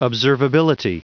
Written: observability

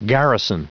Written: garrison

